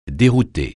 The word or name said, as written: dérouter